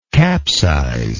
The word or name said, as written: capsizal